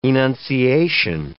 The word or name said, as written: enunciation